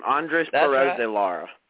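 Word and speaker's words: Andrés Pérez de Lara: And also last week we had the pleasure, slow it down just a little bit, from Rev Racing, Andres Perez de Lara, is that right, Kyle? He come on the show. – Yeah, Rowdy, you didn't butcher his name. Andres Perez de Lara.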